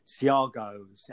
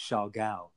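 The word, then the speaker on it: Siargao: When I was there, I was like, how do you say this name? Siargos. And everyone's like, no, it's Chagall.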